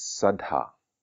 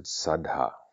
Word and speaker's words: saddha: And the particular power to talk about is Sadha. Sadha. So even that is sounds like a powerful word, Sadha, which is spelt S-A-D-D-H-A,